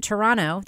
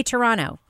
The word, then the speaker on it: Toronto: And speaking of which, I have been told by our listeners that if we don't pronounce it Toronto, they're going to give us a ton of shit. So I'm just going to go on a limb and just say it, guys. Say Toronto. Do not pronounce a second T.